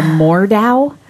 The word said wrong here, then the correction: Mordeo: Oh, Mordeo. – Mordeo.